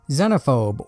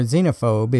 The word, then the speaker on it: Xenophobe: In the next episode, I will be covering a really fun game, Xenophobe, or Xenophobe if you prefer the American pronunciation.